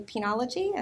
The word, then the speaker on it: penology: But we also have what Jill writes about in her book as the new penology.